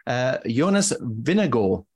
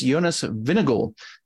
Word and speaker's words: Jonas Vingegaard: I looked up the correct pronunciation, as Jonas would indeed say it himself, and it is Jonas Vingegaard. So that is the correct pronunciation if you're doing it the right way.